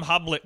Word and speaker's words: Hoblit: I'm calling him Hoblit because it rhymes with Hobbit.